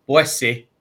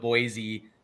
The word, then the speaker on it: Boise: And I'd like to recognize the way that you pronounced Boise. Because I've learned that, I've always pronounced it, Boise, but the locals out here pronounce it the way that you pronounce it.